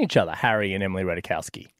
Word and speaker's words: Ratajkowski: And the biggest theory is that it was because he was avoiding his two exes, Olivia Wilde and Emily Ratajkowski.